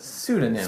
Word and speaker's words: pseudonyms: Pseudonyms. Prosciutto names. That's what I heard? – How do you say it? – Pseudonyms. – Spell it. – It's with a P, but you don't pronounce the P.